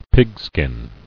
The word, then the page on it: Pigskin: pig·skin